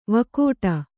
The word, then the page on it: ವಿಕಟ: vika'ta